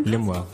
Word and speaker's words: Lemuel: Limwell.